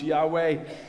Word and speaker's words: Yahweh: And this word Yahweh is the four-letter word YHWH, pronounced Yahweh in English.